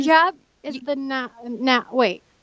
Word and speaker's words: hijab: Well, tell me, what does it mean to you to wear your hijabi? Am I saying that right? – Hijab. Yeah. – Hijab. No, there's no I at the end.